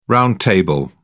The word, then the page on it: round-table: British English